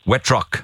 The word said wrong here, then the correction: Wittrick: I think she's an Australian. – Wittrick. Yeah, I am.